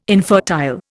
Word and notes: infertile: Indian

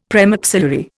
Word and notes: premaxillary: Indian

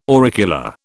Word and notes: auricula: British English

